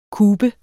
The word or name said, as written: kube